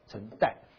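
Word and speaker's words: 陈埭: 然后这个陈代的晋江的这个字很特别，这个发音是代数的代，代替的代，陈代，全部姓丁，两万八千人叫万人丁，那边叫九香郭，小小村庄。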